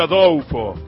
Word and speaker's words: Adolfo: En realidad Antonio Adolfo, porque los brasileños la L la pronuncian como una U.